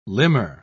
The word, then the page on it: limner: British English